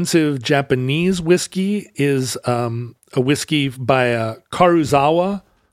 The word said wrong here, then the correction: Karuizawa: Karuizawa, I guess, is how it's pronounced.